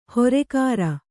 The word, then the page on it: ಹೊರೆಕಾರ: horekāra; Kannada